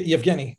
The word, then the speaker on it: Yevgeny: Ye- Yevgeny, but Yevgeny.